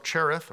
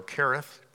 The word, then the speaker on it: Cherith: a place called the Brook Cherith, or Kerith, depending on how you want to pronounce that.